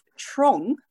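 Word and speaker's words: Tranås: in the village the swedish village of trong and I've just had my uh, Swedish friend confirm the pronunciation, but that's spelled T R A with a little dot over it, um, N G.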